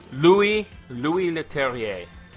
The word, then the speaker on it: Louis Leterrier: Louis, how do you say his name? Louis Leterrier? – Louis Leterrier.